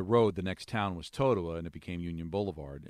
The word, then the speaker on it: Totowa: How do you pronounce T-O-T-O-W-A? – Totowa.